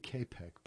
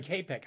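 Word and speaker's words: Čapek: Is it Kopic or Tropic? – What are we talking about? – The writer, the author of RUR. – Oh, I pronounce it K-Pec, but I could be... – K-Pec.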